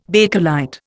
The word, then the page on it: Bakelite: Indian